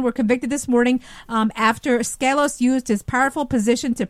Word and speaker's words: Skelos: Skelos, is that how you pronounce him, John? – Yeah, I think you say it's Skelos. I've seen it pronounced Skelos, Skelos, yeah.